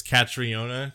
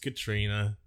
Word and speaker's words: Catriona: always say her name is Catriona because that's how it's spelled. But sorry, dorks, that's not how you pronounce an Irish name like that. It's just Katrina.